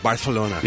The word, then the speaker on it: Barcelona: That's how you say it properly. Barcelona. – You say it with Barcelona. – A TH? – Barcelona, yes.